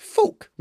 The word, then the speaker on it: Phuq: P-H-U-Q, he said. I'd pronounce that fuck. Seriously? Your trainer's name was fuck? He pronounced it more like fook, Magda said. But you pronounce it like the dirty word.